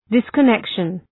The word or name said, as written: disconnection